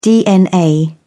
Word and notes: dna: British English

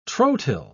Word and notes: trotyl: British English

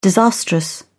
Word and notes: disastrous: British English

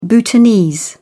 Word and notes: bhutanese: British English

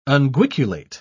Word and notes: unguiculate: British English

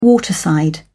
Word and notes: waterside: British English

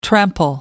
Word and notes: trample: American English

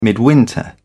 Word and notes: midwinter: British English

